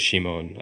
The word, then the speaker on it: Shimon: And if you look at their name, it's Shimon. Like she, you know, death and moan for gate. – Mm. – So that's also how you pronounce Simon in Japanese is Shimon.